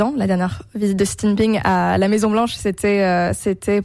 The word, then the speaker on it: Xi Jinping: Vous prenez pour point de départ la visite, c'était la semaine dernière, de Xi Jinping, cette fois c'est bien prononcé, à Washington.